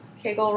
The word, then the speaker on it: Kegel: Some people say Kegel. I say Kegel. – I do too. – Kegel rhymes with bagel.